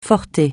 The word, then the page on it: forsythia: francais